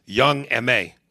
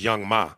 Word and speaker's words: Young M.A: Young M.A. And for a long time, I thought her name was Young Ma, but I think it's pronounced Young M.A.